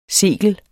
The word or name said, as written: sekel